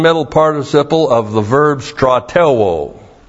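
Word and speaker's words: Stratelwo (στρατεύω): And when you have it with the verb that we have the verb stratelwo.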